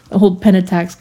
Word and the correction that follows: Pentax: kind of... not Pentax. – Pentax. – Is that how you say it?